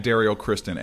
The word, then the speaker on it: Derrial Christon: It's at Daryl Kristen, and I spell it DERRIALCHRISTON because a lot of people do not know how to spell my name.